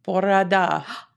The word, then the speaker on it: bore da: Borada.